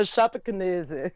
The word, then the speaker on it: Chapecoense: And then I know you've pretty much nailed this pronunciation, haven't you? – Yeah, Chapecoense. – That's it.